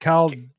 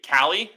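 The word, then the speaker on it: Calle: Callie. I'm going to do it for you. Callie. So it's like C-A-L-I, but L-L-E. – Callie.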